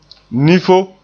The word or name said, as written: nifo